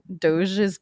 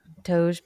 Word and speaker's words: Doge: I didn't look up how to say it, but it's spelled like Doge meme. Hang on. Doge's Palace. I'm such a professional. – Not like Dogecoin? – Yeah. Doge Palace pronunciation. Let me see. Play, you rat. – Play! – The sickening influence that I've had on you. Play, you rat. – I think it's Doge. I'm pretty sure.